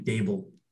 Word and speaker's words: Daboll: I'm taking Brian DeBall. Hope I said that right. DeBall. Yeah. What? – Brian Daboll. – Daboll. Dude, it's spelled D-A-B-O-L-L. There's no Daybell. It's not, where the hell did that come from?